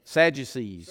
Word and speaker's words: Sadducees: Sadducees. – Sadducees.